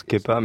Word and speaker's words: Kepa: Kepa, mais c'est pas anglais, c'est basque, c'est le surnom de ma grand-mère, ça se prononce Kepa, mais en anglais ça se prononce Kepa, mais j'avais pas trop pensé à ça.